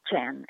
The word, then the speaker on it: Tjan: It's pronounced Chan, as if it's written C-H-A-N, but T-J-A-N.